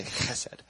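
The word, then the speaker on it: Chesed (חסד): The word for steadfast love in scripture is chesed. Chesed with the ch in there.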